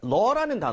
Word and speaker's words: law: father-in-law 그리고 뭐 brother-in-law 뭐 sister-in-law 뭐가 됐든 간에 우리가 law라는 단어가 있습니다. 그렇죠? 이거 발음할 때는 law가 아니라 law. 이렇게 입모양 조금만 벌려서 하는 거 잊지 마시고요.